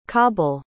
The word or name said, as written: qabil